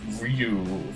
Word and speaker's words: Ryu: Actually, it's pronounced Ryu, first of all.